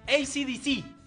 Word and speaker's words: AC/DC: No, ACDC. Con acento en la C.